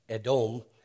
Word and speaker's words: Edom: First of all, the word Edom, I'm saying Edom, it's actually pronounced Edom. It sounds like the Hebrew word for red, which is Adom. Edom, Adom. It also sounds like the Hebrew word for man, Adam.